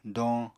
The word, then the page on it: doñ: apatani